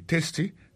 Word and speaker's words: tasty: Food, F-O-O-D, ça veut dire manger. Tasty, T-A-S-T-Y, ça veut dire goût. Goût dans bouche. T-A-S-T-Y, vous prononcez le testé, ça veut dire goût dans bouche.